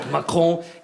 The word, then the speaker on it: Macron: It did sound a bit like laryngitis, but Macron.